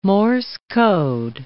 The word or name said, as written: Morse code